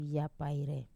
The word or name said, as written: apai